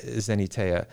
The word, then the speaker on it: xeniteia: Well, that's roughly right. My Greek is not good enough to tell you whether that's exactly right. – Okay. – Zenatea is okay. How I pronounce it. – Okay, that works. X-E-N-I-T-E-I-A, Zenatea.